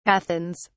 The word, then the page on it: Athens: British English